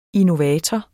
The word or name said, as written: innovator